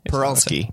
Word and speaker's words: Parulski: Parulski. – Yeah. – Oh, so it's like a, it's not a per.